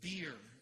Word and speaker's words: bier: And so he walks over and he touched the bier. It's pronounced that way. It's spelled B-I-E-R, but it's pronounced like the beverage.